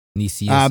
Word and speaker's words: Nicieza: Nicieza.